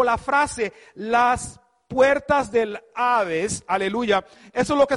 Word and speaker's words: Hades: Y la palabra Hades viene del griego, que se pronuncia casi igual que en el español, Hades,